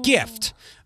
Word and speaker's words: GIF: They're putting out a special jar in conjunction with Giphy, or explaining that GIF has a hard G pronunciation, like how you'd say gift, thus the difference between Jif.